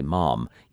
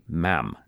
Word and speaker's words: ma'am: And "ma'am," interestingly, the way Americans say it, so you wouldn't say "mom," you'd say "ma'am," as Americans say it.